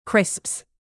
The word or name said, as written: crisps